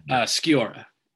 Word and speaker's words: Sciorra: We're talking about the 1998, I guess, love romance movie, What Dreams May Come, starring Robin Williams and Annabella Sciorra. I don't ever know how to say her name. – Sciorra. Annabella Sciorra.